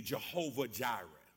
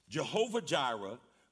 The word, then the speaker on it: Jehovah Jireh: We pronounce it in English typically Jehovah Jireh. Jehovah Jireh, the Lord who provides.